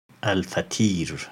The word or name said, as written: Al-Fatir